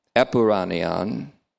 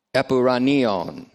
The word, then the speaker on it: epouranion: Epuranion is E-P-O-U-R-A-N-I-O, omega O, N. Epuranion. Looks like this if you put it in English uncials. E-P-O-U-R-A-N-I-O-N, long O.